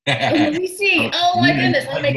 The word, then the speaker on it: Merisi: Because it spells like Maurice, but it's actually pronounced Merisi. It's my wife's maiden name. She's Leona Merisi, so very Italian.